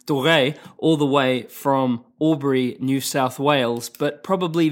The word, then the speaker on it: Albury: He's out of Albury, and that's how you say it, isn't it? – Yeah.